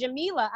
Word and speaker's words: Jamila: My name is pronounced Jamila. I've been called Jamila. Jamila, Jamilia. And I'm like, why are you adding letters to my name?